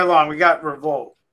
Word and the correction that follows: Revolut: Am I saying it right? Revolut. Yeah,